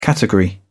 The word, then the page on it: category: British English